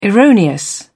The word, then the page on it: erroneous: British English